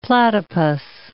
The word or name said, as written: platypus